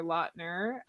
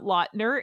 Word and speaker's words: Lautner: Also, by the way, his last name's not Lautner; it's Lautner, mm-hmm.